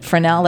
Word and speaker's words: Fresnel: Now, you mentioned you just acquired the Fresnel, that's how you say it? – Right, yeah.